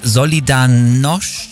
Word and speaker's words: Solidarność: Der das russische Regime mit seiner Solidarność, ja, so würde man es aussprechen, ja, erfolgreich gestürzt hat und dann Polens erster demokratisch gewählter Präsident wurde und für sein Engagement den Friedensnobelpreis bekommen hat.